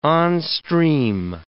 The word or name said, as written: onstream